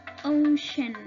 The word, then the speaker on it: ocean: Ocean, ocean, al igual que la C, en la palabra océano, la vamos a pronunciar como sh, y la T en la palabra contaminación, la vamos a pronunciar como sh.